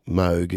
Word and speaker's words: Moog: And you had that guy Moog, or Moog as it's pronounced,